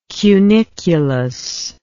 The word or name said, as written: cunicular